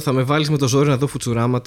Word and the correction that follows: Futurama: τώρα. Φιουτσουράμα, πώ ε, λέγεται κανονικά. Πε να Φουτουράμα. – Φουτουράμα. – Φουτουράμα, γιατί okay. η προφορά του future είναι πολύ δύσκολο. Ε, Futurama. – Futurama, οκ. Ό,τι θε πάντω, από θέμα προφορά ε, το έχω.